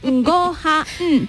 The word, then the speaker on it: gohan: Que dices goha mm", por ejemplo, que creo que es arroz en japonés, pues sería, se, escribe en gohan, pero se pronunciaría, pero es goha. Mm".